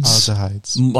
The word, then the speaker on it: aldehydes: Aldehydes? – Aldehydes.